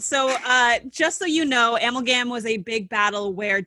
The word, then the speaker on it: Amalgam: Our podcast title is, I don't know if I'm pronouncing it right. – Amalgam. – Yes. Okay.